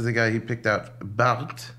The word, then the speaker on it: Barthes: Barthes, Roland Barthes.